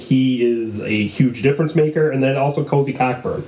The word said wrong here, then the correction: Cockburn: It's spelled Cockburn, but it's pronounced Coburn.